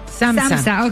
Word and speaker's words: SAMHSA: Por ejemplo, tenemos el SAMHSA. Esta sí está muy difícil. – A ver, pronunciation. – SAMHSA. – ¿Cómo? – SAMHSA. ¿Cómo se dice? – SAMHSA. – Ok.